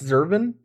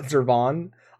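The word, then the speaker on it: Zurvan: Let's talk about the Warring Triad, and I guess it's pronounced Zervan, not Zervon.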